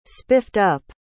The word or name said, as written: spiffed-up